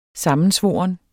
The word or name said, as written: SAMMENSVORNE